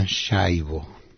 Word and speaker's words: Schiavo: Schiavo, que en Estados Unidos pronuncian Shaivo.